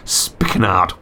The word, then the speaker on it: spikenard: A spikenard, which is it's pronounced spikenard.